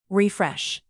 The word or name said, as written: refresh